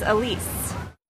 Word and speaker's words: Elise: My name is Elise. – How do you spell it? – E-L-I-S-E.